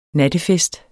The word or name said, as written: nattefest